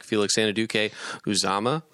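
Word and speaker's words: Felix Anudike-Uzomah: I like Felix Anadike Uzama. Is that how you say it? – Yep. – Is that how we're saying it? – Yes.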